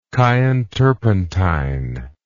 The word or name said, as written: Chian turpentine